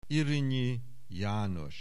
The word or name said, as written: Irinyi, János